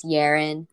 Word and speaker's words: Yaren: And when I got older, I found out it's a little bit easier if I just tell them, it's easy to pronounce my name if you think of it as Aaron with a Y in front, and then that makes it Yaren.